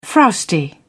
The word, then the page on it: frowsty: British English